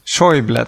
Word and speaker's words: Schäuble: Schäuble, tack.